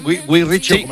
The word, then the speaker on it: Guy Ritchie: Lo he dicho bien, Guy Ritchie.